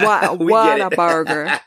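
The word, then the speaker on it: Whataburger: Wat-top burgers. I always say it wrong, but you know what I'm saying, Renee. – Everybody say it wrong. Yeah. – Wat-a-burger.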